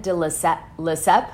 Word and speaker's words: de Lesseps: Today's episode is about Luann de Lesseps.